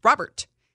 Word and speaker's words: Robert: It's just because he's saying it's the way you pronounce it in Spanish. You don't go Luis Robert. And people are like, it's Robert. I'm like, no, fool ain't French. Like, he's not French.